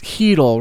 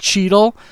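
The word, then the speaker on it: Chytil: And he's like, I don't know how to pronounce his name because I'm pretty sure one of the letters is silent. He's like, it's Heedle, right? It's not Cheetle.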